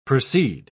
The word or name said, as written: proceed